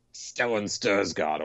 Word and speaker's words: Stellan Skarsgård: Best supporting actor, Stellan Skarsgård, or is that how you say his name? – Yeah.